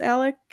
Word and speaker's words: Alex: I think she asked Alec. Okay, this was really confusing to me because it was spelled Alex, but he pronounced it Alec with a C.